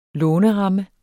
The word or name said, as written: låneramme